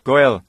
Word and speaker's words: Goel: Di dalam Alkitab, dalam kitab Rut, waktu Anda membacanya, Anda akan mengetahui orang semacam itu disebut Goel, G-O-E-L, disebut Goel,